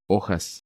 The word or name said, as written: Hojas